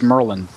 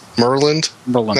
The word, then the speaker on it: Maryland: It's not Maryland, it's Merlin. – Merlin.